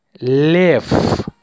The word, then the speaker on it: Лев: Лев. Лев. We pronounce it лев, ф, not в.